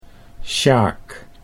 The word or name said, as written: Sjaak